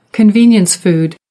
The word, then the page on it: convenience food: American English